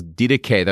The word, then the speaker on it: Didache: The book is Didache. That's how you pronounce it in Greek.